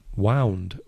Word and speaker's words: wound: And the past, as we mentioned, of wind, irregular verb, is W-O-U-N-D. Wound. Coincidentally is herida, if you pronounce it wound.